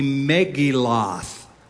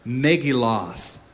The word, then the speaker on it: Megilloth: This book, the Book of Lamentations, is part of a section of the Bible called the Megilloth. I'm pronouncing it, Americanizing it on purpose. Megilloth, or Megillot is the Hebrew pronunciation.